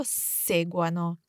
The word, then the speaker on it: seguano: che loro seguano. So again, we want to emphasize the stem when it comes to loro, che loro seguano. – Okay, so segua, segua, segua, seguiamo, seguiate, seguano.